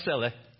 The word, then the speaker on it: Selah: It's pronounced Seleh.